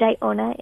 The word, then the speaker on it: Iona: Iona is my middle name, and it gets so many different pronunciations. But it's Evelyn, E-V-Y-L-E-N, and Iona is I-O-N-A.